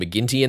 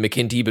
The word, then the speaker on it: McKinty: my head just keeps inserting the pronunciation McGinty in there because I just read Adrian McKinty, but with a G. – Sure, sure. – So I know that's wrong, but please forgive me if I continue to make that slip up because I inevitably will.